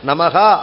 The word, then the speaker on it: நமஹ: சிவாய நமஹ, நமஹ அப்படிங்கிற விசர்க்கம் இருக்கு, அந்த விசர்க்கத்தை ஹ அப்படின்னு சொல்லி முடிக்கணும்.